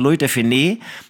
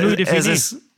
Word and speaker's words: Louis de Funès: sondern Louis de Finé. Jetzt haben mich die Franzosen nach 700 Jahren aufgeklärt, nee, es heißt Louis de Finesse.